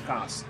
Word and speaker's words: kaas: In, like, the Netherlands, they call their type of cheese, they call it, kaas. That's how you pronounce cheese. It's kaas. Gouda is what we call Dutch cheese.